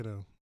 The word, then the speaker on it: Keanu: Keanu.